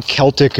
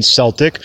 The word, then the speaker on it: Celtic: But that night, I went home, looked up the definition, the pronunciation guide, and sure enough, like I already knew, you know, Celtic is the most common pronunciation, and Celtic was also on there. They included the alternate pronunciation Celtic.